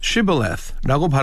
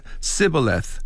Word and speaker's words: Shibboleth: 그것을 Shibboleth라고 발음을 해야 되는데 Sibboleth 했다는 것이지요.